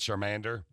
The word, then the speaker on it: Charmander: Charmander? – Mm-hmm.